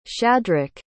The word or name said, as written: shadrach